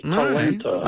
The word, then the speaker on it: Talanta: So just to get the name right, her name is Atlanta with a T up front? – Yes, Talanta. Talanta.